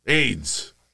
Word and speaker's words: Ayds: es AY o sea, A-Y-D-S eh, pero se pronuncia igual, AIDS.